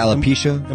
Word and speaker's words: alopecia: But anyway, I guess she has alopecia. – Yeah, alopecia.